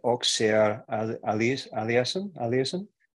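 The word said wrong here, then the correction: Auger-Aliassime: Auger Aliassime.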